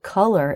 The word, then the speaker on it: color: Collar is spelled C-O-L-L-A-R, and color is spelled C-O-L-O-R. So we have an A-R ending and an O-R ending, but they are pronounced the same way, right, David? It's the schwa-R, sound.